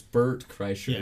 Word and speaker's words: Bert Kreischer: So there was something that that Tom Segura and Brett Kreischer, I always pronounce it right. It's Bert Kreischer.